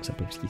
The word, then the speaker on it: Sapkowski: Les romans alors, je ne sais pas si je le prononce bien, mais d'Andrzej Sapkowski. Donc, Sapkowski, c'est bon, je prononce bien.